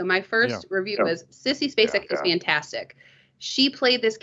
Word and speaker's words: Spacek: I was going to interrupt you before, Sean, with something you were saying about I can't say her name. Say her name for me. Spacek. – Spacek, yeah.